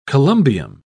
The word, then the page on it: columbium: British English